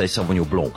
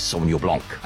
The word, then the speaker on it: Sauvignon Blanc: You see, if you're French, you say Sauvignon Blanc. I'm not French. I'm South African. And the correct way to pronounce the grape in South Africa is Sauvignon Blanc.